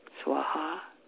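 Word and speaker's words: svāhā: It's spelled S-W, long A-H, long A, swaha. Swaha.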